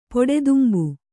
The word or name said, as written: ಪೊಡೆದುಂಬು